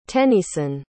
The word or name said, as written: tenneyson